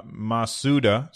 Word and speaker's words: Masuda: Uh, that was producer, director, and composer uh, Masuda. Masudua. Uh, Masuda, sorry, I said his name wrong.